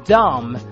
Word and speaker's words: dumb: 扮的演的像一个什么样子 play，而这个 dumb 注意这个 b 是不发音的，